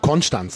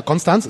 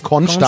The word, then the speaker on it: Konstanz: Das Schöne ist, dass du auch Konstanz sagst. Ja, das ist ja auch die richtige Aussprache. Genau. Viele Norddeutsche sagen ja Konstanz, es ist Konstanz.